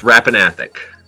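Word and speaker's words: Rappan Athuk: but I don't remember how it's actually pronounced. – It is pronounced Rapanathok.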